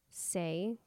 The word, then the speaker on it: Saie: And then I use the Say, is that how you pronounce it? S-A-I-E. – Yeah.